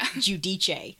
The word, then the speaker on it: Giudice: Giudice.